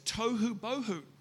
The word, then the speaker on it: tohu bohu: And in Hebrew, that's tohu bohu. And it rhymes.